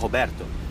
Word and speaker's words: Roberto: Yeah, my real name is Roberto and I'm half Brazilian. And when Roberto is pronounced in Portuguese, it sounds like Roberto.